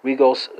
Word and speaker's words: rigoles: It's going to be ridi, spelled R-I-D-I. French speakers translate the Italian to the French. It's going to be rigoles, spelled R-I-G-O-L-E-S. That S is silent. Dutch speakers are working with lakit, spelled L-A-C-H-T.